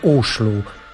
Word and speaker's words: Oslo: Ten zespół zwie się Oslo S, bo zdaje się Oslo po norwesku wymawia się Oslo.